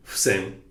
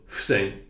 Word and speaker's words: Fusen: And this was a clever sort of pun or you know, bit of wordplay, because the kanji for women's vote, Fusen, and universal vote, Fusen, are pronounced, as you might have already noticed, identically as Fusen.